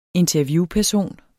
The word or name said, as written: interviewperson